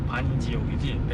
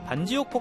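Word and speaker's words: Bản Giốc: 이게 영어 발음으로 반지옥이지 베트남어로는 만접이라고 하는 곳이에요.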